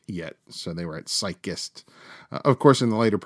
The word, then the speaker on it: Psychist: And I hope I pronounced this right. Psychist Or, yeah, I think that's it. Psychist. It's P-S-Y-C-H-I-S-T. Psychist.